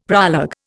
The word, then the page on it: proleg: Indian